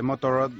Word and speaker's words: Motorrad: Estuve en una ocasión de la BMW Motorrad. Pues, ¿lo he dicho bien? – Por ejemplo, creo que sí. – Sí, Motorrad.